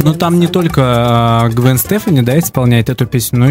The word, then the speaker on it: Стефани: Если на итальянский манер, то правильно, конечно, Стефани. А по-английски ударение всегда ставится на первый слог. Будет, соответственно, Стефани. Если хотите кто-то по-французски назвать, надо говорить Стефани. У них-то последний слог ударения ставится.